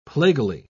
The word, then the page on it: plaguily: British English